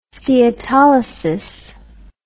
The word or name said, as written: steatolysis